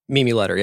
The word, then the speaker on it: Mimi Leder: Mimi Leder, is that how you say it? – Mimi Leder, yeah.